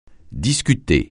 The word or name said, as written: discuter